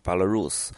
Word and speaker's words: Belarus: 一个就是说，它实际上的英文 Belarus，呃，没有俄这个发音啊，就是实际上是，呃，Belarus，Rus 是罗斯嘛，就是实际上就是白罗斯的意思啊。